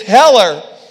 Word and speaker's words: Heller: I said, just because my last name is Heller and my last name rhymes with Zeller doesn't mean that I'm James.